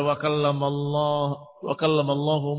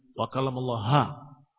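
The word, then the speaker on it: wa kallamallahu (وكلم الله): Begitu membaca wa kallamallahu Musa taklima, dia menyatakan wa kallamallaha. Diam nih diam, enggak ngomong, cuma nyeletuk wa kallamallaha. Bukan hu. Di antara pendengar dan mungkin yang membacanya, iya, jangan-jangan wa kallamallaha. Khamilina azakumullah. Bahaya atau bukan bahaya? Bahaya. Atau dia mengucapkan bukan satu kalimat, satu huruf. Dibaca wa kallamallahu, dia menegur. Ha. Yani ha bukan hu.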